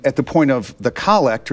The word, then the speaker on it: collect: And it's pronounced collect, but we might think of it as collect because it's the prayer where the priest collects everybody's individual intentions, and with a single voice, he's standing in the person of Christ, the head of the church.